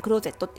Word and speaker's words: XZ: めしたりとかあとうちのお客さんにもねあのおすすめしたりとかで結構使わせてもらってるのがクローゼットっていうアプリでとねアルファベットで XZ って書いてクローゼットって読む